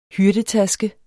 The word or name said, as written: HYRDETASKE